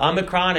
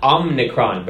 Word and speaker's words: Omicron: You know, Delta, Omicron? I said, I call it Omnicron, but it's Omicron or something.